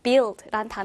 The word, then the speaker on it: build: 자, 참고로 여기에서 스펠링을 보면요. build. 자, 우리가 흔히 u 발음을 뭔가 어라든지 우 발음으로 알고 있지만 특이하게 이 단어는요. 마치 u가 없는 것처럼 그래서 발음이 build라고 된다라는 거좀 특이하니까 이 부분도 체크하면 좋겠어요.